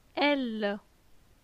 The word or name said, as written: Elle